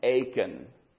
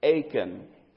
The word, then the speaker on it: Achan: And a man by the name of Achan. Say his name with me, would you? Achan.